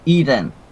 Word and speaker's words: Eeden: Am I saying this right? – E-E-D-E-N? Eden.